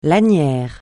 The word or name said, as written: lanière